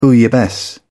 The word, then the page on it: bouillabaisse: British English